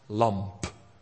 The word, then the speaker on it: lamp: En het lam met een M is haar lamp. Met een P.